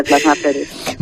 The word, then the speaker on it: plasmaféresis: Sí. Plasmaféresis. Lo has dicho perfectamente, plasmaféresis.